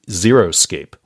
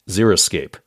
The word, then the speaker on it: xeriscape: But so many people pronounce it xeriscape, like the number zero, which is totally incorrect. The word xeriscape, beginning with X-E-R-I, xeriscape.